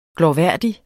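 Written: glorværdig

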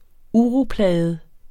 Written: uroplaget

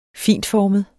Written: fintformet

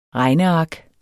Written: regneark

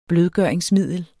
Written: blødgøringsmiddel

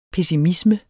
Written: pessimisme

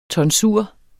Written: tonsur

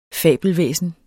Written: fabelvæsen

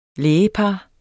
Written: lægepar

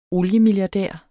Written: oliemilliardær

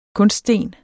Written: kunststen

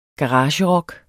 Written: garagerock

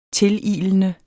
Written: tililende

